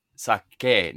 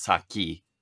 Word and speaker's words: sake: So sake, and it's pronounced sake, not saki, just so everybody knows.